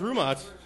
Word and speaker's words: Rumot: It's not Roman? – No, it's actually pronounced Rumot. I actually found that out in like seventh grade. I was pronouncing it wrong my whole life.